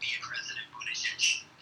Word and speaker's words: Buttigieg: But it's like, yeah, he's a booty judge. So I don't mean it in that way. I mean, that is the best mnemonic to understand how his name is pronounced. Buttigieg.